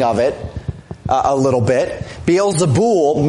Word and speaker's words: Beelzebul: Beelzebub, actually pronounced Beelzebul in the Greek. Beelzebub is the Hebrew.